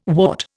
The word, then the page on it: wart: Indian